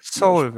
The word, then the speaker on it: Seoul: Ich weiß nicht, wie es ausgesprochen wird. Soul, so, äh, wie, wie heißt es denn jetzt richtig? Ich weiß immer nicht. – Seoul, würde ich sagen. Das, also, es ist so wie die äh, koreanische Hauptstadt.